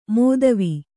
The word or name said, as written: ಮೋದವಿ